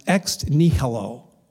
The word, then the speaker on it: ex nihilo: It's pronounced ex nihilo.